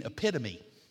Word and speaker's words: epitome: I said, I think, son, you mean epitome. You put the emphasis on the wrong syllable.